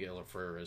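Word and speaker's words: El-ahrairah: Frith says to Elafretha, or Elafrera, I think Elafrera is how you say it.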